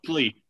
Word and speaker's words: Kuechly: You know, this feels, yeah, like Eric said, the Luke, you know, Luke Kuechly, I can't really say his name. I'm sorry about that. – Kuechly. – Yeah. His, you can't role, say Luke Kuechly? – I don't know.